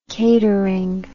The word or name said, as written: catering